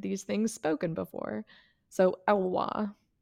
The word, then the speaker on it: Elwha: How do you say the name of that river? Elke River? Yeah. – The Elwha. – The Elwha. Okay.